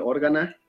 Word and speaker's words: Organa: Bueno, pues ahorita el que mencionaron, este Jimmy Smith, el senador Bail Organa, perdón, así es como se pronuncia, es que de niño pronunciaba, decía uno Organa, no sé por qué. – Yo creo que por órgano, quizá.